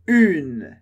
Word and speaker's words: une: Então, a pronúncia é un, masculino, e une, une, com o biquinho do início. De novo, un, e une, une, uma.